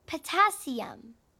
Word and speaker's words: potassium: Group 1 consists of lithium, sodium, potatoium. – I think you mean potassium.